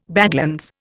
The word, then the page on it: badlands: Indian